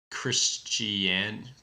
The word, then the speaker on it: Christiania: And he starts off in Christiania. I don't know how to pronounce it. – You're pronouncing it correctly, I think.